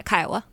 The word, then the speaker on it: Kiowa: She is a member of the Shinnecock Indian Nation and affiliated with the Kiowa tribe of Oklahoma. Did I say that correctly? – Yeah, Kiowa.